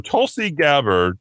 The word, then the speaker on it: Tulsi Gabbard: So Tulsi Gabbard, I know she is a former U.S.